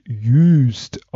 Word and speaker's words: Juist: Also diese Insel, die Juist geschrieben wird, wird Juist ausgesprochen. Mhm. Mit einem langen Ü, das vergesse ich auch immer wieder.